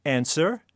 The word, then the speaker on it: answer: Or answer? – W.